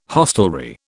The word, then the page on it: hostelry: British English